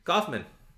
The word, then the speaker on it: Coffman: Uh, you can also find me on Twitter at Harrison, first name, Coffman, last name.